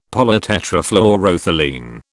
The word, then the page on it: polytetrafluoroethylene: British English